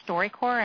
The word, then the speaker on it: StoryCorps: So the program StoryCorps, and I'll spell that, StoryCorps, so core like Marine Corps,